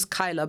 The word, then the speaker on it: Kyla: Like, my name's Kyla, but people always read it as Kayla because it's got that silent A at the beginning.